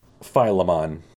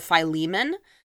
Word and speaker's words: Philemon: In the Greek, it looks like the emphasis is on the second syllable, so that might be closer to Philemon.